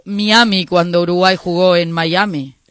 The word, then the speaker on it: Miami: No, Miami. – En español es Miami, castellanizalo. – No. – El wifi. – No, el M con el I se pronuncia Mai.